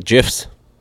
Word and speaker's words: GIF: But the G in GIF is actually pronounced J.